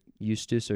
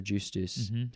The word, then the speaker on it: justus: Semper justus or justus, depending on the church or regular Latin, et peccator.